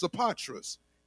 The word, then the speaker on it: Sopatras: his name is actually, we see it as Sopater, but his name is actually pronounced Sopatras in the Greek, meaning his name means savior of his father.